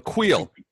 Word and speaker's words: Kreel: Kreel, like quill, but Kreel. – Like wheel, but quill? Kreel? – Correct, yeah.